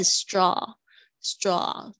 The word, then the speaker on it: straw: 这个习惯了，所以 straw 注意一下 a w 的发音，它是这个啊的声音，它是不它是不是发 o 的声音？是 straw straw 嘴巴微微打开 straw，all right，napkin 这个也是比较容易发错，那个 nap 加上 can，napkin 就是餐巾纸，我们之前有讲过那个 toilet paper 那个厕纸。